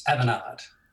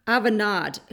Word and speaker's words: Avanade: And one of the first ones you had that you've listed in your bio was out in Singapore with Avanade. Did I pronounce that correctly? – It's Avanade. – Avanade. Ooh, Avanade. – Touch of French pronunciation. – Avanade.